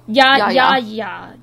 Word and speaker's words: Yahya: Yahya. And it's spelled Y-A-H-Y-A.